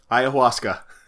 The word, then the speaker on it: ayahuasca: Ayahuasca.